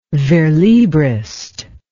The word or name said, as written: verslibrist